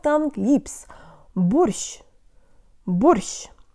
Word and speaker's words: борщ: The tongue is low for bo but high for sh, so it has to travel fast from low to high in one syllable. Let's go. Jo tang lips. Borsh.